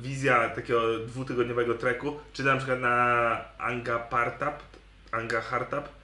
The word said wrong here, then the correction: Nanga Parbat: to ta najwyższa góra. – Nanga Parbat. – Musisz się najpierw nauczyć wymawiać.